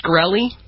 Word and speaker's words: Shkreli: It's Shkreli.